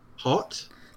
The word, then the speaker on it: haute: Um, how do you pronounce this word? – Hot. – Hot. Hot. – H A U T E. Hot yes.